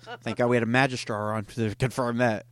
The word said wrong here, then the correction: Magister: Is it magistrar or R? – Magister. Magister. – Magister. I've been saying it wrong.